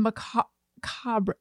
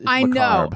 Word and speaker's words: macabre: Macabre is wrong, too. It's macabre. – Know. I try to just give... Just a hint of the last syllable. Like I just a kiss of sound on the last syllable.